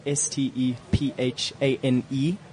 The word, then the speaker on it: Stephane: It's spelled S T E P H A N E. So it's it's almost like Stephanie, but it's oh, Stephane.